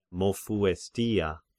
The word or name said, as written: Mopsuestia